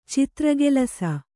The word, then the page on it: ಚಿತ್ರಗೆಲಸ: citragelasa